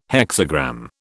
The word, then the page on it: hexagram: British English